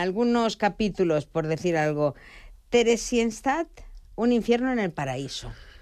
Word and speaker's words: Theresienstadt: Theresienstadt.